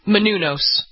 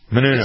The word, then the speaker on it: Menounos: Menounos. – Menounos. – It's not a yos at the end. – I never... – That would make her Spanish. She's Greek.